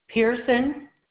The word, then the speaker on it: Pearson: It's Avery Pearson. – And how do you spell that last name? – P-E-A-R-S-O-N.